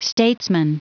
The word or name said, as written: statesman